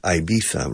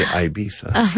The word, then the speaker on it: Ibiza: Well, in England, they pronounce it Ibiza, right? – Oh, my God. – I think they say Ibiza. – Yes. – Con la zeta ceceada, o sea, la zeta española.